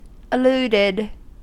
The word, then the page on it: alluded: American English